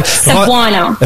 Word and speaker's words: Cebuano: Cebuano. – Okay, so if I say, Cebuano.